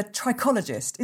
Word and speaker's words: trichologist: Steve O'Brien is a trichologist. Is that how you say it, Steve? Trichologist? – Yes.